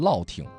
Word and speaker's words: 乐亭: 比如说唐山的乐亭，河北唐山乐亭，正常人谁能想到这个乐最多还有个月的这个读音。